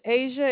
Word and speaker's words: Asia: My name is Asia. It is spelled A-S-I-A. – A-S-I-A, Asia.